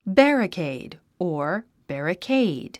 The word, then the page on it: barricade: bǽrəkèid; バラケイド